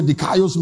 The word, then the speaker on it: dikaios: The word righteous there is the Greek word dikaios. Or dikaios. That's the right way to pronounce it. So I'll spell it to you. D-I-K-A-I-O-S. D-I-K-A-I-O-S. Dikaios, that's the right pronunciation.